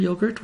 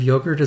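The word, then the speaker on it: yoğurt: Now, the word in Turkish is also spelled with a G, like in English, but apparently G in Turkish has a very different sound. More like the English W, I guess. So it's more like yogurt.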